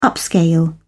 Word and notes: upscale: British English

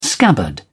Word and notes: scabbard: British English

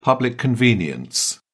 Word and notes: public convenience: British English

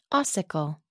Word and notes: ossicle: American English

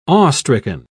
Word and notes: awestricken: British English

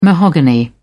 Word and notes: mahogany: British English

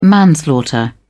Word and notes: manslaughter: British English

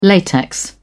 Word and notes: latex: British English

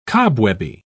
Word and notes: cobwebby: British English

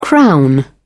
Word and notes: crown: British English

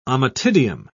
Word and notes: ommatidium: British English

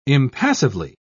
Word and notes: impassively: British English

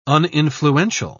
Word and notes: uninfluential: British English

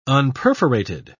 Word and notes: unperforated: British English

